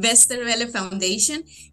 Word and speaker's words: Westerwelle Foundation: Westerwelle Foundation es de Alemania. Entonces, ¿en qué consiste bien esta fundación? ¿Me equivoqué un poco en esta presentación o lo dije bien? – No, lo dijiste bien. Westerwelle Foundation.